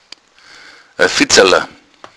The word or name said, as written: fitzala